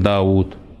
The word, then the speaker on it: Дауд: Допустим, Дауд. Дауд. Пишется Дауд, а читается Дауд. Так ведь? Хотя там вау нету.